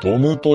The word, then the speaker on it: DOM: ドムというのは、